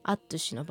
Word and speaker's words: アッチュシ: アクセントがくる「ちせ」「カムイ」に対して「アッチュシ」の場合は「母音が 2 つ